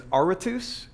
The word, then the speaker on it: Aratus: I think the way you pronounce the name is Aratus, A-R-A-T-U-S.